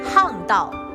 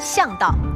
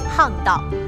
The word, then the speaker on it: 巷道: 我们再看第二题，以下词语的正确读音是 A，巷道，B，巷道，请作答。来看一下正确答案，应该是 A，巷道。